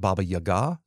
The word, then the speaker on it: Baba Yaga: That's always the Baba Yaga. Also sometimes pronounced, more properly pronounced, something more like Baba Yaga.